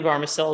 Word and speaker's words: Bahrmasel: But Ellie Bahrmasel is the CEO of Further Faster Venture Studio.